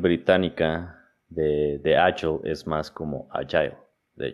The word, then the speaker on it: agile: estoy como estaba, checando este tema de la pronunciación y pues se supone, estaba, investigando, estaba leyendo y se supone que la pronunciación correcta es como ayo. Eh, como así como, como si no existiera algo entre la G y la L, agile.